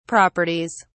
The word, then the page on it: properties: British English